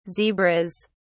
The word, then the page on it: zebras: British English